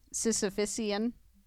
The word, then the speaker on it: Sisyphean: That's a Sisyphean task. – Right. – Did I say that right? – I think you did. Say it again. – Sisyphean.